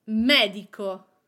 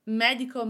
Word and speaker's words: medico: The same for medico. Medico means doctor.